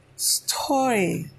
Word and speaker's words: storge: Then you have storge. It is spelled S-T-O-R-J-A-Y, storge. That's the Greek term, but the English spelling is S-T-O-R-A-G-E.